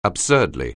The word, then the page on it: absurdly: anglais